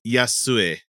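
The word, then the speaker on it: Yasue (يسوع): No, it's not pronounced Jesus. – In Arabic, it's Yasue or Yasue.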